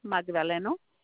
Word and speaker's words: Magdaleno: My name is Maria Magdaleno. – Okay. Excuse me. Can you spell that last name for me, please? – M-A-G-D-A-L-E-N-O.